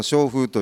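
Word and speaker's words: 松風: 私どものこの商風という社名ですが、松と風と書きまして商風と読みます。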